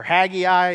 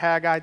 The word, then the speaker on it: Haggai: We're going to go to Haggai, or Haggai is another way to pronounce it. I'm going to pronounce it Haggai today, so you don't think I'm like trying to overdo it here.